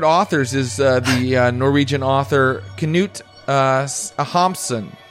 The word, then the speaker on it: Knut Hamsun: It's I always pronounce it Knut Hamsun, and he wrote a book I liked called Growth of the Soil, and I'm right. – Ah. – Knut Hamsun.